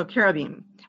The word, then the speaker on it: cherubim: It's the CH is actually a K sound. And I right, like Hanukkah. – On the end in Hebrew is always pronounced eem. – So cherubim.